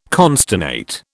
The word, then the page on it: consternate: British English